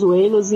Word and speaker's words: Zweilous: Esse que você tá falando, eu tô vendo aqui o nome. É Deinos, Wailos e Hydreigon.